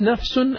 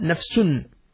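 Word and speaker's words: نفسٌ: And you can see here the word nafs, sometimes it is pronounced nafsun and sometimes it is pronounced nafsin. Sometimes it is with the dhamma, nafsun. And sometimes it is with the kasra, nafsin.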